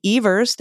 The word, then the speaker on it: Evers: Democratic Governor Tony Evers, thank you everybody for my Wisconsinites for reminding me that it's pronounced Evers, hailed the ruling, saying he looked forward to submitting proposed maps for the court to review.